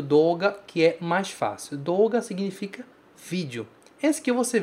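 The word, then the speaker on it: Doga (どうが): Repara que a gente fala do, mas a gente pronuncia do. Doga. Tá escrito D-O-U, você pronuncia D-O-O.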